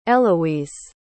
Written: Elouise

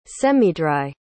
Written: semidry

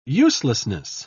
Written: uselessness

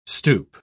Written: stoup

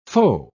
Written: faux

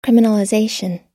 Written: criminalization

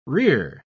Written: rear

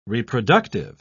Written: reproductive

